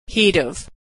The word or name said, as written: he'd have